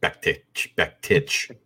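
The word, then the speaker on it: Bektić: Bektik versus Gregory. I'm taking Bektik. – Mirsad Bektik. – Even though I believe it's actually pronounced Bektich, as if there's a CH on there for whatever reason.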